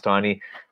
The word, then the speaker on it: Taney: And of course, along the way, uh, Justice Taney, Chief Justice Taney, who wrote the uh, pronounced or spelled Taney, but pronounced Taney,